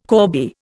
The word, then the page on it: corbie: Indian